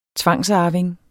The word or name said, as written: tvangsarving